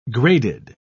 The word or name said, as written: graded